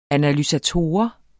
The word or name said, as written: analysator